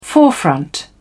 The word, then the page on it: forefront: British English